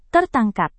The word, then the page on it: tertangkap: indonesian